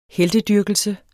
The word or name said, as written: heltedyrkelse